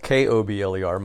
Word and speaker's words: Kobler: And my last name, by the way, is spelled Kobler.